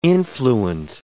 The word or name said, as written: influent